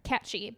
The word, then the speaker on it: Cat Sith: It's spelled like Cat Sith, like Sith Lord. – Love it. – But it's catchy.